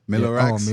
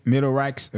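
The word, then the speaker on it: Mellow Rack: Comment prononcer nom amen. – On fit Melo, Melorax. – Oh, Melorax, OK, yeah.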